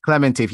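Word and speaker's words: Klementieff: I don't know how to pronounce her last name. Kivanjif. Well, I thought it was Clementif. – Clementif. Yes, it's Clementif. Okay.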